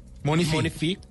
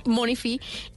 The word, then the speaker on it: Monefi: Pues encontré una aplicación que se escribe Monefi pero sería como Money... – Monifi. – Sí, Monifi.